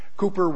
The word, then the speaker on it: Cowper: Apparently that's how you pronounce his name. It's spelled C-O-W-P-E-R, but it's pronounced Cowper.